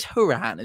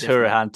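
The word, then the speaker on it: Hourihane: It is Hurahan, isn't it? Or is it something? – It is Hurahan.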